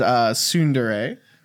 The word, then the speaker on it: tsundere: I know that when you say Japanese words in English, if there's a T at the front, sometimes you don't say it. So I pronounce it as tsundere.